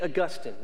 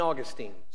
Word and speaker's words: Augustine: And it is St. Augustine, not St. Augustine. St. Augustine's a city in Florida, and it's a great city. I'd encourage you to go. But St. Augustine of Hippo, spelled the same way, he was an incredible theologian and a priest in the early church.